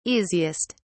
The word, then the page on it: easiest: British English